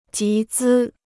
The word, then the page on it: 集资: jí zī